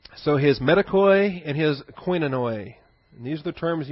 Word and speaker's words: koinonoi: Metakoi and koinonoi. K-O-I-N-O-N-O-I. Koinonoi. It has both the short O, the Omicron is the short O, and the Omega, this W-looking letter, is the Omega, is the long O.